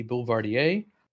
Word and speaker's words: Boulevardier: Besides the Chinar Negroni, I've also had the Boulevardier, or as Brooks, how do you say it, Brooks? – Belvedere. – Oh, I hate that. I don't. It's Boule. That's no. – Boulevardier. Yeah. Bell. – See, I can see the deer at the end. The D-I-E-R.